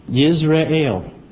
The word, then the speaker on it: Jezreel: Now the word Jezreel is interesting. It's number 3157, and it's pronounced Yisrael, and means Elohim will sow, meaning to disseminate, to plant, to fructify, to bear and conceive seed, as set with a sower.